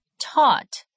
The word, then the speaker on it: taught: Buy, bought. Teach, taught. Think, thought. The GH is silent.